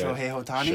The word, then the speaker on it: Shohei Ohtani: Shohei Ohtani. – Shohei Ohtani.